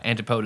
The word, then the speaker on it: antipode: So I think it's, yeah, antipode is the correct pronunciation.